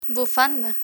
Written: bufanda

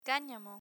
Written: canamo

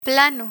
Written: plano

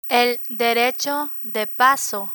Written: el derecho de paso

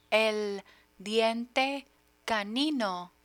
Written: diente canino

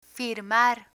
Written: firmar

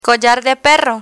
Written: collar de perro

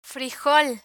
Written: frijol

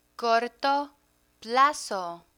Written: corto plazo

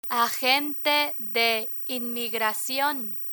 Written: agente de inmigracion

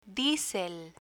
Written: diesel

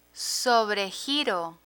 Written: sobregiro

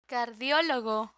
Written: cardiologo

